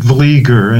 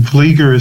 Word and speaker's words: Vlieger: Oh, Howard Vlieger, and Vlieger is spelled V-L-I-E-G-E-R, and he'd make a wonderful guest on your program, by the way.